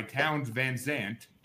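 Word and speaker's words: Townes Van Zandt: It's number 498, Poncho and Lefty by Towns Van Zandt.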